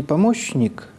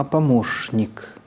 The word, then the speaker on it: помощник: А слово «помощник», которое пишется ровно с таким же «ща», вдруг читается не «помощник», а «помощник» с точки зрения литературного произношения.